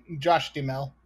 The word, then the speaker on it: Josh Duhamel: Okay, so if we got Katherine Heigl, how do you say his name? Josh Duhamel? – Josh Duhamel. – Duhamel? – Yeah, basically the H and the A.